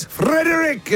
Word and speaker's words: Frederick: Yes, Frederick is how you say his name. – So Frederick. – You've got to roll the R.